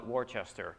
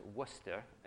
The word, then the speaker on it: Worcester: If you're not from Massachusetts, you call it Worcester, but since I lived up there, it's Worcester, and that's how they say it.